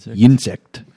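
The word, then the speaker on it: Ynsect: It's Y-N-S-E-C-T, but I believe it's still pronounced insect.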